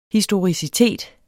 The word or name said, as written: historicitet